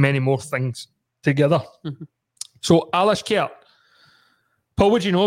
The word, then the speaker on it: Alashkert: Alashkert. Did I say that right? – Alashkert. – Alashkert at Ibrox on Thursday night.